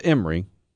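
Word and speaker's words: Amiri: No, it's pronounced Emery. – But it's A-M-I-R-I.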